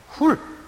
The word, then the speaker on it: Hur: Ben Hur, H-U-R 영어식 발음. 그 미국 촌놈들이 히브리어를 할 줄 몰라서 허라 그랬죠. 훌 그랬죠?